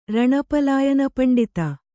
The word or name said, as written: ರಣಪಲಾಯನಪಂಡಿತ